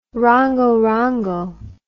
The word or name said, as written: rongorongo